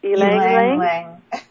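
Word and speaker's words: ylang-ylang: Lavender, mandarin, yang, yang. How do you say that again? – Ylang ylang. Yes.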